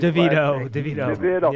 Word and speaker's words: DeVito: Tommy Revito or what, how you say it? – DeVito. Yes.